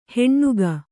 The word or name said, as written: ಹೆಣ್ಣುಗ